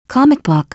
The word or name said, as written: comic book